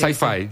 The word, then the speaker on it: sci-fi: Sci-fi.